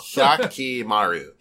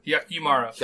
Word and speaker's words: Hyakkimaru: Hyakkimaru. – Hyakkimaru. – Hyakkimaru.